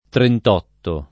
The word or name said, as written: trentotto